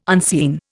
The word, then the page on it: unseen: Indian